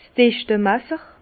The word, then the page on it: 's Dichtemasser: Bas Rhin; Herrlisheim